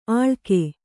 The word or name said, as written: ಅಳಗೆ